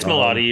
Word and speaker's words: Milioti: You can't say your name wrong. – It's Milioti, you're right.